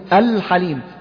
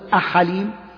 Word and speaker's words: الحليم: شايف تقول الحليم لا تقول أحليم مثلا، أجليم لا، الجليل، الكريم، الودود، ال شايف نطقت اللام طبعا من غير سكت بعدها، الكريم، الودود، الخبير، الفتاح، العليم، القيوم، اليوم، الملك، الهادي.